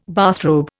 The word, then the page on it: bathrobe: Indian